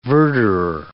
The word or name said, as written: verderor